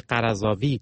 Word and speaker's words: قرضاوی: شیخ یوسف القرضاوی یا آنطور که در زبان فارسی قرضاوی تلفظ می شود، روحانی 82 ساله مصری است که سالهاست به یکی از جنجالی ترین چهره های جهان اسلام بدل شده.